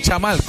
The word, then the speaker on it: Yamal: Es Yamal, como ella dijo, es Yamal.